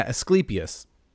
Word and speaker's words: Asclepius: Am I saying that right? Hang on, let me look at this real quick. I think it's, yeah, Asclepius.